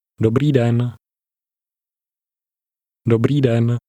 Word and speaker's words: Dobrý: Dobrý. – Notice the pronunciation of the ending.